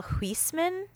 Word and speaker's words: Huisman: From the Netherlands, like Holland. So Michael Huisman, if you would do it French.